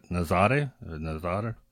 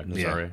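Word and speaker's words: Nazaré: Nazare. 100 sure. foot wave. – Is that the one in Portugal? Yeah, Nazare.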